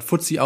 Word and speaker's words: FTSE: Dann gibt es noch FTSE Russell, also FTSE, FTSE, spricht man FTSE aus, und den S&P Global.